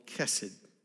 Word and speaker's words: chesed: In the Hebrew, there is a word for love called chesed. Chesed. It's spelled with an H, but it's pronounced with the K.